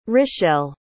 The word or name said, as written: rishelle